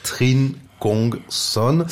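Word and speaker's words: Trịnh Công Sơn: Gatai Kra Mi, un extrait de cette chanson du Brassens vietnamien, je vais le prononcer à l'européenne, Trinh Kong Son,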